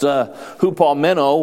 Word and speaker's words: hupomeno: Patience is a Greek word which is pronounced hupomeno, which means hupo meaning under, meno to remain or to abide under, who has the idea of endurance.